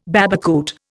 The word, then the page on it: babacoote: Indian